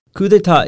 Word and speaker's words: coup d'état: Coup d'état is the French word.